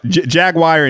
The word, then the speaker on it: Jaguar: Jaguar and Jaguar are both like acceptable pronunciations.